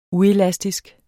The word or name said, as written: uelastisk